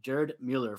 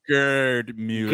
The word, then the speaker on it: Gerd Müller: Also, Messi scored his 8th go-ahead goal at the World Cup, breaking a tie with Paolo Rossi, Luis Suarez, and Gerd Müller for most. – Hold on, you said it wrong. It's Gerd Müller.